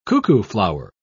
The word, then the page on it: cuckooflower: British English